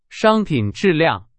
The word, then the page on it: 商品: Chinese